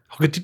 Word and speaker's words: Hogarty: It's Hogarty, like Doggarty. Hogarty, Doggarty.